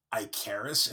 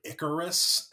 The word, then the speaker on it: Ikaris: Uh, I also found out that I've been like pronouncing Ikaris incorrectly my entire life. Oh yeah, because his fake name is Ike Harris.